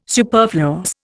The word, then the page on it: superfluous: Indian